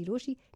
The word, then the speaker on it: Rochie: मेरा नाम है मिर्ची रोशी, नाम की स्पेलिंग है ROCHIE और मेरे साथ लाइन पर है मिस्ट्री कॉलर.